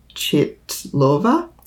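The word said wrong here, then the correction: Chytilová: Hitilova. It's a soft T.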